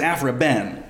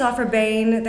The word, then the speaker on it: Aphra Behn: Now that's the correct pronunciation of it, because I always thought it was Afra-Ben. – Yeah, I think it's Afra-Bane.